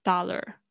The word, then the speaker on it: dollar: 我们现在就是来注意一下它的发音：dollar、 dollar，OK，D O L L A R，dollar、 dollar。哦，这个 A R 是发二的声音。